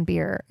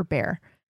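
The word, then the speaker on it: Behr: They don't do any build up there, but the chemistry between, you know, Shiri Appleby and Jason Bear is just like, is that how you say his name? Is it Bear or Bear? – I think it's Bear. – It's Bear.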